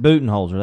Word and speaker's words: Budenholzer: I butchered that name. The coach for the Bucks. Do you not say the name? – I think you said it right, didn't you? – Budenholzer? – Budenholzer, that's it.